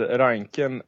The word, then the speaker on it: Reinkind: Harald Reinkind är ju en förtjusande typ, tycker jag. – Harald Reinken är väldigt duktig. – Bättre uttal.